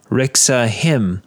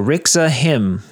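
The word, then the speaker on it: Reaksa Himm: Or you could just go onto YouTube and look up Rixa Him. Now, his name is spelled R-E-A-K-S-A. His last name is H-I-M-M. So Rixa Him.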